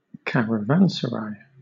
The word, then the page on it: caravanserai: British English